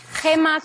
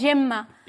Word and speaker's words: Gemma: Si lo pronuncias bien, se pronuncia Anna, igual que Gemma con dos M's, en catalán es Gemma.